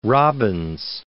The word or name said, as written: Robbins